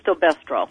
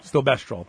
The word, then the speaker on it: Stilbestrol: Diethyl Stilbestrol. – Stilbestrol.